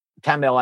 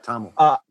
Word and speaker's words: Tamil: Tamil, I believe that's the correct pronunciation. – I think that's right, Tamil.